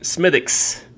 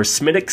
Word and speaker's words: Smithwick's: It is spelled like Smithwick's, but it is Irish. It's an Irish ale produced by the Guinness folks. So apparently you're supposed to pronounce it Smithix or Smithix, something like that.